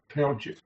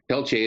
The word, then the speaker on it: Peltier: I think in Louisiana that'd be pronounced Peltier. – Pelche in Canada too, but I watched part of this 20-minute thing that they had on Democracy Now.